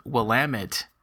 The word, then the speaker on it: Willamette: I think in Oregon there's a area called Willamette, like there's Willamette Falls or something like that.